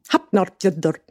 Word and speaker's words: Hafnarfjörður: Repeat after me. Hafnarfjörður. It's one of those where the F sounds like a P and the D sounds like a D, and that's what you have to say.